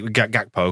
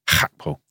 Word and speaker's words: Gakpo: Could you say Gakpo, because that's how we pronounce a G in this country. But the native pronunciation is Hakpo.